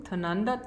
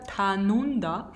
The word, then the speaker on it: Tanunda: Also einmal googeln am besten Chateau Tananda, Tanunda.